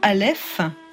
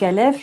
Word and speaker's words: Aleph: Un petit mot d'abord de ce nom, maison Aleph, qui prête joliment à confusion. – Donc Aleph, je peux vous rassurer, je pense que 8 personnes sur 10 le prononcent Alep, mais en fait donc c'est A-L-E-P-H et c'est la première lettre commune de tous les alphabets de cette région si chère.